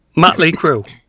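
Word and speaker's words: Mötley Crüe: How do you pronounce this? – Motley Crue.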